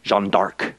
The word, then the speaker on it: Jeanne d'Arc: Jeanne d'Arc is really the way I hear people pronouncing it, which is her father's surname.